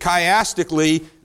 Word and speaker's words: chiastically: Disputation 3 and 4, which remember the word that starts with a C but's pronounced with a K? Thank you. It chiastically, these kind of fit together, okay?